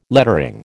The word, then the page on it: lettering: American English